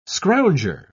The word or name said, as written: scrounger